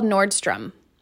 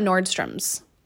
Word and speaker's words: Nordstrom: It is called Nordstrom. There is no S. It's not Nordstrom's.